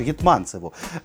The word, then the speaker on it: Гетманцеву: Адже він у нас, друзі, дістається Данілу Гетьманцеву, точніше, Гетманцеву. Е, його ж Гетманцев, да, правильно говорити? – Ну, Пост- так. Постійно плутаю. – Ну, хай буде Гетманцев.